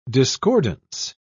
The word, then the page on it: discordance: British English